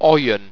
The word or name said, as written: oien